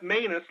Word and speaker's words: Maness: And Seth Maness, long A.